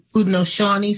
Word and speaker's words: Haudenosaunee: If any of these Haudenosaunee, is that the way you pronounce that name? – Haudenosaunee survived the soldiers' aim to starve them by torching their crops.